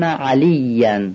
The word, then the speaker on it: علی: اینجا عینو باید صحیح بگیم، یعنی ما در فارسی عینو معمولا به صورت همزه میگیم، میگیم اشهد ان علی، ولی عین عربی یه مقدار غلیظه، اشهد ان علی. پس دقت کنیم که عینو یه مقدار غلیظ تلفظ بکنیم و بقیه مواردش تقریبا تکراریه.